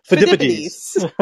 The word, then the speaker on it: Phidippides: Phidippides. – That's actually what it looks like, how it's pronounced.